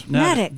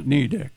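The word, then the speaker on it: Neddick: It rhymes with medic, according to this. – Nedic. – Nedic, then, not Nedic. – It sits on the small Nubble Island. – What? – Nubble. N-U-B-B-L-E.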